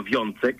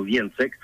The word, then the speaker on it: Wiącek: od imienia staropolskiego Wiącek, czasem wymawianego jako Więcek, stąd Wiąckiewicz czy Więckiewicz, familie tak.